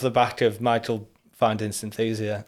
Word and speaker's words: Synthesia: Oh, Synthesia.